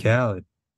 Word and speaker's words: Khaled: How do you pronounce that name? Khaled? Yeah, I'm DJ Khaled.